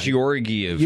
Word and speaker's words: Georgiev: Georgiev.